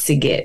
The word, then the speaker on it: Sigit: With a hard G, so it's Sigit.